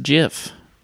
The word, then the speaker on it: GIF: And it's probably the earliest argument of GIF versus JIF. – Hmm. You think Christopher Lloyd caused that? – I think he started the hard versus soft G in that frame.